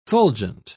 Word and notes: fulgent: British English

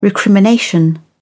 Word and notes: recrimination: British English

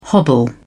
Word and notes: hobble: British English

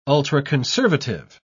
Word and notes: ultraconservative: British English

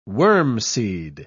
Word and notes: wormseed: British English

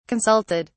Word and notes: consulted: British English

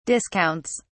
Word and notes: discounts: British English

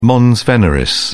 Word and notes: mons veneris: British English

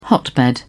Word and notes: hotbed: British English

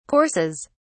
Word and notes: courses: British English

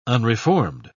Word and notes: unreformed: British English

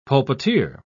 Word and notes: pulpiteer: British English

